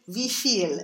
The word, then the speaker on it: wie viel: If you look it up in a dictionary, it actually says wie viel. And the stress syllable is the second one, right? – Yeah.